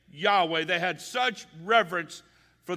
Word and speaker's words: Yahweh: So the closest we can come to pronouncing this name would be Yahweh, or its English form, which is Jehovah.